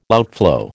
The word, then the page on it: outflow: American English